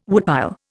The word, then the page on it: woodpile: Indian